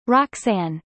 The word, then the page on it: roxanne: American English